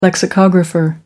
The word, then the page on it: lexicographer: American English